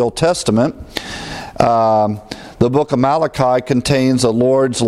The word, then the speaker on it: Malachi: We pronounce the book Malachi, the I, but in Hebrew, actually, the I is pronounced as an E in Malachi. Malachi in the Hebrew pronunciation.